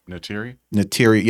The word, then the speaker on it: Neytiri: Neytiri, how you say your name? – Neytiri, yeah.